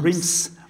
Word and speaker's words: Reims: And how do I pronounce this again? Because, of course, we always say Reims. – Reims. Or people say, wait. – I say, is Reims.